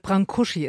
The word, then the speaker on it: Brâncuși: Brâncuși ist die richtige Aussprache.